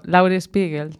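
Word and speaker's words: Laurie Spiegel: En este caso, pois utilizamos a peza, esta mesma peza de Lauri Spiegel. Pronuncio ben? – Bueno, tampouco sei se se pronunciare moi ben, pero Lauri Spiegel. – Spiegel, vale, sí. Pois Spiegel.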